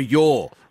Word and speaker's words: Yaw: Is it Y-A-W or your? – Your, as in, yeah, your food.